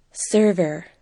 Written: server

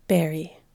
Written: berry

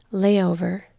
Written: layover

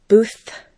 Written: booth

